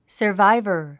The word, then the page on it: survivor: sə r váivə r; サバイバァ